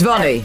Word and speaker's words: Dhvani: D-H-V-A-N-I. – D-H-V-N-A-I. My God. D-H-V... – No, D-A... – It's Vani.